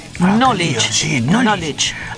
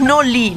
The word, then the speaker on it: knowledge: Knowledge. Ay, ¿por qué no me dijo que era Noli? – Knowledge. – ¿Por qué no escribe Noli? – No es Noli, knowledge.